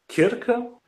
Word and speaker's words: Circe: I heard people, Circe, I heard that before, Kirke, okay. And depending on whether you're German or English, I think, I also think someone pronounced it as Tilta or Tilke, or it's weird. I think Circe is the official English pronunciation of that Greek goddess, so maybe use that.